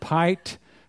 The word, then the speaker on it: pight: Stick to the tense that he hath pight. That's past tense for pitched. So it rhymes with fight.